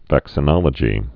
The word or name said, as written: vaccinologist